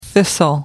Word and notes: thistle: American English

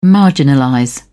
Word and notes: marginalize: British English

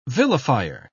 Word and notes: vilifier: British English

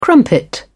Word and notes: crumpet: British English

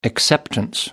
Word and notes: acceptance: American English

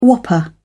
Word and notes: whopper: British English

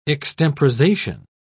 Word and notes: extemporisation: British English

